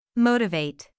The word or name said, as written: motivate